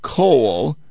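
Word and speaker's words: kol (חול): In other words, this Hebrew word, pronounced kol, C-H-O-L, both denotes the beginning and the secular.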